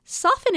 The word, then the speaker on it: soften: Soften is a weird word. There's a silent T in there. Soften. Will soften.